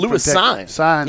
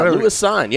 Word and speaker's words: Lewis Cine: How do you pronounce it? The kid Lewis Cine. Yeah, Lewis Cine.